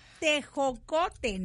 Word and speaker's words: tejocote: No, oye, yo no queriendo lo disimulé y tecojo. Está peor. Está peor. Este tejocote, ¿no? – A ver, ¿cómo se dice? Tejocote.